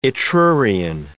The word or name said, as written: Etrurian